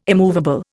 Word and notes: immovable: Indian

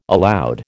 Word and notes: aloud: American English

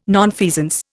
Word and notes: nonfeasance: Indian

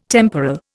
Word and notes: temporal: Indian

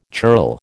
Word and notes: churl: American English

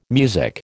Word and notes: music: American English

